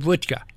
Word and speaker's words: vodka: You know that they pronounced it vodka. – Is that right? – Not vodka. – Okay. – Vodka.